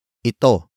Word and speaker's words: ito: And finally, we have ito. Ito. Ito. Ito is spelled as I-T-O.